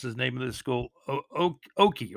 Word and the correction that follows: Ocoee: right? O-C-O-E-E, Oki? Oki, I believe they're called. Oki 29, West Orange 28. If I mispronounce the name, please let me know. Ocoee. I think it's Ocoee, actually.